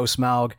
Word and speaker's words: Smaug: O Smaug.